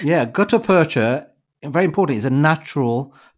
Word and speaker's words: gutta percha: Now, gutta percha.